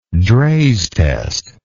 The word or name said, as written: Draize test